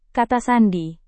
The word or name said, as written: kata sandi